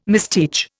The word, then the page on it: misteach: Indian